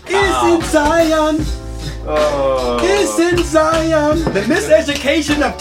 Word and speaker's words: Zion: Zion. You said it right.